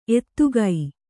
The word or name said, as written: ಎತ್ತಿಗ